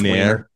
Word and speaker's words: Wiener: In class, I've said Weiner, and I looked up the pronunciation today because I'm about to officially teach it more tomorrow. And Wiener. It's Weiner. It's Weiner.